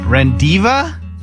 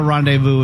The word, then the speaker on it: rendezvous: R-E-N-D-E-Z. V-O-U-S. R-E-N-D-E-Z. V-O-U-S. – Rendiva? Divu? Rendezvous. Used his brain.